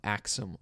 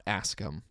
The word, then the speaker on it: Axum: the Ethiopian kingdom of Axum flourished. And by the way, it's Axum, not Askum. I mispronounced it last episode. My mistake.